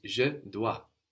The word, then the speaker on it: je dois: I must or I have to is je dois, Je dois. – related to the word dette in English. That's where that D comes from, je dois. And this is spelled D-O-I-S, dois. So we end in an S, but we don't hear it, je dois.